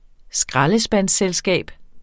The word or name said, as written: skraldespandsselskab